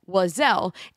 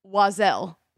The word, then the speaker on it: Oiselle: There's a Seattle-based company called Wazelle. It's actually spelled O-I-S-E-L-L-E. – And say that again? – Wazelle. – Wazelle. – Yeah. – And it's spelled with an O.